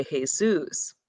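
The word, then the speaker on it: Jesús: Usually because it's with the Latin, Mexican, in those cultures, it's, they don't say Jesus, they would say Jesus. So there is a difference in the pronunciation a little bit, but in our English translation, it would be Jesus.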